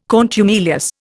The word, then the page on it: contumelious: Indian